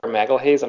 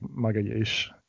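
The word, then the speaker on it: Magalhães: ultimately from Edgar Magalhaes. I'm not sure I'm saying his name right. – It's pronounced something like Magalhaes.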